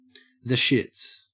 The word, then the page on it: shits: Australian English